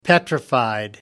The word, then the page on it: petrified: American English